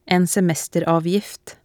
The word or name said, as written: en semesteravgift